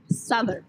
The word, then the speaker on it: Southwark: How do you pronounce Soutwark? – That is a place in London, I believe. I know how to say it. Southwark. – I think Southwark. That's right too. I'm 99.99999%. – Southwark. But people say Southwark. – Yeah, because that's what it looks like. It's spelled Southwark. Like. It looks like it's spelled like Southwark.